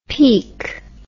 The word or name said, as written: Peake